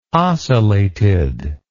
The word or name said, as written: ocellated